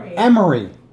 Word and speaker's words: Emery: Emery.